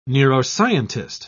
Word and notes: neuroscientist: British English

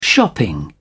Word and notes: shopping: British English

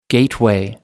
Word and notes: gateway: American English

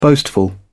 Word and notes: boastful: British English

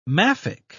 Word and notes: maffick: British English

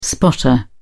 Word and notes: spotter: British English

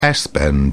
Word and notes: s bend: British English

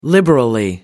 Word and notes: liberally: British English

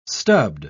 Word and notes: stubbed: British English

